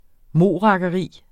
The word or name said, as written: morakkeri